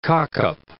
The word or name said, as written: cock-up